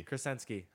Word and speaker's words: Kricensky: Kricensky.